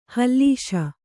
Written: ಹೊಲ್ಲೈಸು

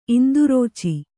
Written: ಇಂದುರುಚಿ

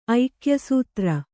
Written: ಐಕ್ಯಸೂತ್ರ

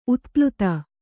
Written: ಉತ್ಪ್ಲುತ

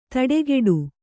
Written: ತಡೆಗೆಡು

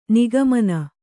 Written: ನಿಗಮನ

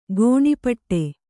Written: ಘನಪಾಠ

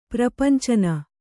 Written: ಪ್ರಪಂಚನ

